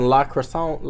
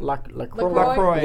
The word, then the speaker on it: La Croix: La Croissant? La Croix.